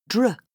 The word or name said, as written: dr